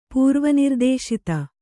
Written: ಪೂರ್ವನಿರ್ದೇಶಿತ

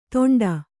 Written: ತೊಂಡ

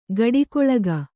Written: ಕೂಡುಗಲಕ

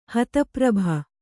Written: ಹತಪ್ರಭ

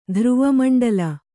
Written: ಧ್ರುವಮಂಡಲ

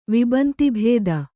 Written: ವಿಬಂತಿಭೇದ

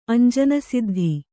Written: ಅಂಜನಸಿದ್ಧಿ

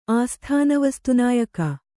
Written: ಆಸ್ಥಾನವಸ್ತುನಾಯಕ

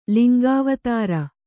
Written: ಲಿಂಗಾವತಾರ

